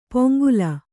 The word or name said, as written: ಪೊಂಗುಲ